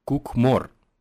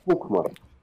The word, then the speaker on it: Кукмор: Скажи, пожалуйста, вот э, на твоей странице в ВК написано, что твой родной город Кукмор. Как, про, как правильно читается, кстати? Да, Кукмор. Кукмор. Кукмор. Кук, да. Кукмор.